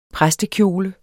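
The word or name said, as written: præstekjole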